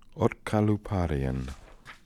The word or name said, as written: otkalooparian